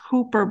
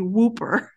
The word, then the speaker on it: Whooper: I looked and it says it's supposed to be pronounced Hooper, but it looks like it should be Whooper.